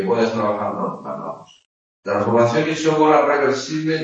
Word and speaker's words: isócora: Transformación isócora, acento en la, o sea, tilde en la O. Isócora.